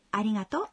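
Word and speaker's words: аригато (ありがとう): В слове «аригато» гласное «о» долгое.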